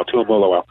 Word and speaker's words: Tuimoloau: JT Tuimoloau took everybody, including me, like a year to figure out how to pronounce his name. Like crazy, but I've got it now, Tuimoloau.